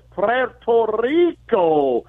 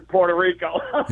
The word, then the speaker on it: Puerto Rico: Now, if you're a Latino, you say it like this, Puerto Rico. But if you're American, you say it like this, yeah, for Puerto Rico.